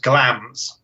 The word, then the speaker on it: Glamis: Well, we should really pronounce it with a clipped Scottish accent, which I won't try and do because I'm not very good at accents. But it's supposed to be pronounced Glams. – Glams. Okay, because I've heard Glams. I've heard Glamis. – Yeah. So, the correct Lowland Scots is Glams, I'm told.